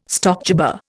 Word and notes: stockjobber: Indian

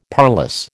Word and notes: parlous: American English

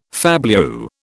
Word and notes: fabliau: British English

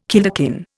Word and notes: kilderkin: Indian

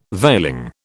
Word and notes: veiling: British English

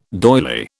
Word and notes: doily: British English

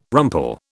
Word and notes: rumple: British English

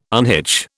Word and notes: unhitch: British English

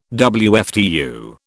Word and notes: WFTU: British English

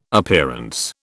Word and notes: Appearance: British English

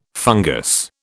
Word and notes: fungus: British English

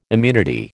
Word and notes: immunity: American English

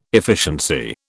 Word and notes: efficiency: British English

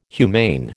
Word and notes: humane: American English

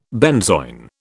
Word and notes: benzoin: British English

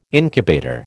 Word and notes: incubator: American English

